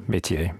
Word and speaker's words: métier: How do you say that? – Yeah, métier. It's a good French word in English as well.